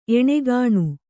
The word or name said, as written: ಏಣಗೋಣ